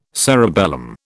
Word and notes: cerebellum: British English

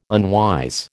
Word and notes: unwise: American English

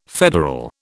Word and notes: federal: British English

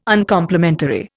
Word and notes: Uncomplimentary: Indian

